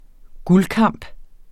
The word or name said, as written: guldkamp